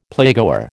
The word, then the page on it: playgoer: American English